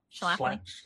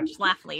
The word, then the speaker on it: Schlafly: But you also, in Jesus and John Wayne, like Phyllis Schlafly, am I saying her name right? – Schlafly. – Schlafly. Yeah, Schlafly. You got it.